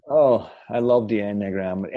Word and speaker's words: enneagram: You have programs for them, but you talk about the an engram. I'm not going to say that right. And I've been that's enneagram. – One of that's it. You say it again. – Enneagram. – It's got too many E's in the wrong places.